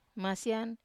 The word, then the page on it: masian: Wamesa